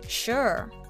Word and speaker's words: sure: Make sure. Yeah, sounds fabulous. Make sure. sure の発音が少し言いにくいかもしれませんが口をすぼめて下を丸める風に